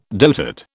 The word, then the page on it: dotard: British English